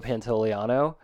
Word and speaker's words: Pantoliano: I always forget the Joe Pantoliano.